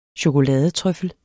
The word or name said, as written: chokoladetrøffel